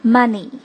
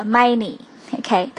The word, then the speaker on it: many: 还有就是很多 many，一定不要发成 money。或者 many，OK，都是很难听的中式的发音了，所以注意小嘴型的 a many。